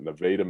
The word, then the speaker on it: Nevada: played in the the Jayhawk League in a place called Nevada, spelled like Nevada, but Nevada, Missouri.